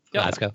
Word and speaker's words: Glasgow: Glasgow.